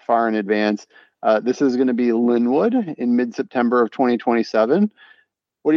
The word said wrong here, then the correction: Lindenwood: I know that they're pronounced Lindenwood. – Oh, yeah, sorry. My bad.